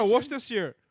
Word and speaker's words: Worcestershire: I think you probably pronounced it wrong, but okay. – Worcestershire sauce. – Worcestershire sauce. – Is that correct? – Yeah, Worcestershire.